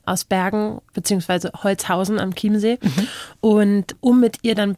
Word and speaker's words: Chiemsee: bin ich dann an Chiemsee gezogen. – Also das war Chiemsee, die Aussprache.